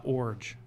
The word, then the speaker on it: Iorg: I-O-R-G. – I-O-R-G. – And it's pronounced Orge.